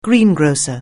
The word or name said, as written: greengrocer